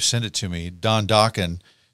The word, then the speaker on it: Dokken: so he just called it Dokken as just kind of a placeholder. – Right. – Right, and he's like, it rhymes with rockin', you know, we'll figure out something else later on.